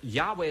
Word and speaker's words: Yahweh: would agree that Yahweh is the correct pronunciation of the title.